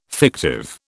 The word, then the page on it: fictive: British English